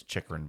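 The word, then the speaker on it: Chychrun: It's Chikrin, by the way.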